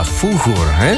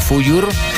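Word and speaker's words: Fújur: Realmente, la pronunciación correcta sería con un poquito de J, Fuyur, Pero bueno, cuando se hizo el doblaje al cine... Se suavizó más, pues aquí lo conocemos como Fuyur, pero sería un poco más Fuyur, como bien apuntaba nuestro amigo Raúl desde Zaragoza.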